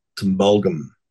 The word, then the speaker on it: Tumbulgum: There's a little town between the Woolloombar and the Gold Coast of Coolangatta, and it's called Tumbulgum, but it's spelled Tumblegum. So, and everybody comes down, they'd beat you over the head if you got that wrong.